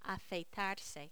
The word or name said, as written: afeitarse